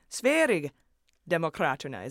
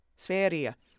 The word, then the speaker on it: Sverigedemokraterna: So I tried again with Sverigedemokraterna, and now you've all come back saying, no, soft G like a Y sound.